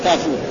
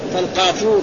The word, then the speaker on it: كافور: كافور ها او شيئا من كافور يجعل في الماء ويغسل به الغسل الاخير فالقافور والكافور لفظه إيه؟ لفظان بس ينطق ينطق بالقاف وينطق بايه؟ بالكاف وكذلك كشط وقشط يعني كشط هذا بشين ونزعت وقرأ عبد الله قشط ها عبد الله يعني السماء إيه؟